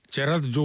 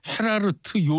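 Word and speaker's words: Gerard: 음악은 제라드 졸링. 예. 영어로는 우리가 제라드 졸링 이렇게 읽습니다만 이게 네덜란드 가수거든요. 그래서 이게 네덜란드 발음으로는 무슨 헤라르트 졸링 뭐 이렇게 발음을 낸대요.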